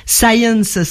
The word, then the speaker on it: Sayens: Science, ça s'écrit, ça se dit comme la science en anglais, mais ça s'écrit S-A-Y-E-N-S.